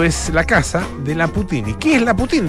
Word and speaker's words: Poutine: Esto se escribe Poutine, ¿ah? eh, francés, Putin.